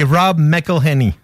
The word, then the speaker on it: Rob McElhenney: C'est Rob McElhenney.